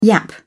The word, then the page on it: yap: British English